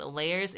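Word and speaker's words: L@yerz: My name is Layers. It is spelled L with an at sign, Y-E-R-Z.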